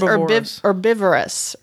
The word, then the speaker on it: Herbivorous: It looks like herbivorous. – It's herbivorous. Herbivorous is how you pronounce it.